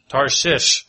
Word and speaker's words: Tarshish: But it is, you got to do the SH at the beginning and at the end. Tarshish.